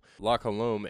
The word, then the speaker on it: La Colombe: La Colombe. Say that right? – Yeah. – Colombe. Awesome. Cool. La Colombe.